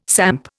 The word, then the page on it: samp: Indian